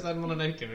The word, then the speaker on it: Mononoke: You said Mononoke again. – Mononoke.